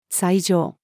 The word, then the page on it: 斎場: female